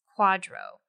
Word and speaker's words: QDRO: And of course, the acronym is QDRO, but it's pronounced quadro.